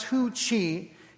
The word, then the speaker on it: Hu Qi: Chinese artist Hu Qi, looks like it's pronounced Hei Qi, but pronounced Hu Qi,